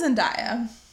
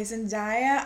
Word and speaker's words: Zendaya: How old is Zendaya? – Okay, Zendaya, I'm going to say she's... – Did I say that right? I think it's Zendaya. – I think it is Zendaya.